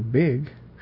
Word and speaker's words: BG: We've got the genealogy, of course, which is our chronology, called the BG, and the acronym is BG, which is pronounced also big, like the word, but you can also get other words like bag, beg, bug, bog, all kinds of different words from the BG acronym.